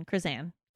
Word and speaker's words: Krizsan: Krizan. – Krizan. Krizan. K-R-I-Z-S-A-N. Krizan. Prison. – Yeah, rhymes with prison.